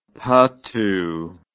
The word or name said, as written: pattu